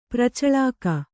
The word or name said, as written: ಪ್ರಚಳಾಕ